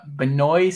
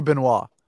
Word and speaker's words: Benoit: All right, we got Benoit Saint-Denis. I think I butchered that. – Definitely. – Yeah. – That's got to be Benoit. – Benoit? Oh, there we go.